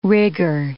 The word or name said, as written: rigger